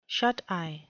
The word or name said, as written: shuteye